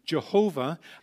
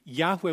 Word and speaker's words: Yahweh: We used to have the pronunciation in the Old King James Version, Jehovah, and it's still in many of our hymns, of course, but it was a wrong pronunciation because of the vowels they had inserted. So we're back to Yahweh. We think that's the way it's pronounced, but you'll find in just about every Bible that's written, the name of the word Lord is capitalized.